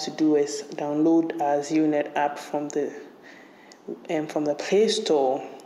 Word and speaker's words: ZeroNet: E-T, ZeroNet, pronounced ZeroNet.